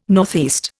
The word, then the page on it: NE: Indian